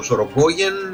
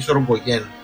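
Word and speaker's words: Sorogoyen: Ο Ροδρίγο Σορογκόγεν, ή Σορογκόγεν, υπάρχουν κάποιοι οι οποίοι τονίζουν στη μία πλευρά, κάποιοι στην άλλη, που θα δώσει ένα masterclass.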